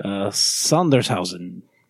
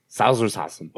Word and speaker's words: Sondershausen: Sondershausen. – Sondershausen.